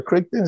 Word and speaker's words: Crichton: We move to Stefan Crichton. Is that how you say his name? – Yes. – Crichton. – Yeah. Tashawn or Crichton, something like that. – Crichton, yep.